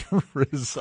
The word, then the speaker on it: RZA: And if my contacts in the hip-hop industry are correct, that's how you pronounce it. I believe it is RZA.